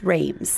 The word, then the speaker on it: Reims: Reims. – How do you spell it? – R-E-I-M-S. Reims. Reims. – Reims. – Reims. Reims. – Don't say the S.